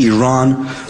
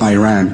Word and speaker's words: Iran: You're pronouncing our country's name wrong. Our country is named Iran, not Iran.